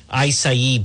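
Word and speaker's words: açaí: Acai Berry, A-C-A-I-B-E-R-R-I, or pronounced Acai, but I pronounce it phonetically so you can find it. Acai Berry, A-C-A-I-B-E-R-R-I, Acai Berry.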